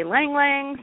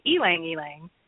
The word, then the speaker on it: ylang ylang: It's spelled Y-L-A-N-G, Y-L-A-N-G. And some people say lang lang, some people say ylang ylang, and some people say elang elang. Any of those ways is acceptable.